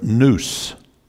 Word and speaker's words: nous: There's another passage, I don't have it marked, where Jesus, in the Greek, he opened their nous. We would spell it N-O-U-S. Nous is the Greek word for mind.